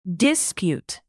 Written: dispute

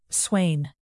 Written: swain

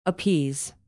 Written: appease

